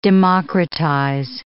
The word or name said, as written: democratize